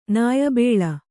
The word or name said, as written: ನಾಯ್ಬೇಲ